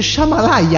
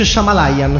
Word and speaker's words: Shyamalan: Si dice Shyamalan. – Ma non c'è una I. – Ho capito, ma si pronuncia così. Come io. – Si pronuncia. – Comunque si dice Shyamalan, scommettiamo.